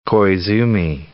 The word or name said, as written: Koizumi